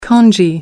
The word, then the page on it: kanji: American English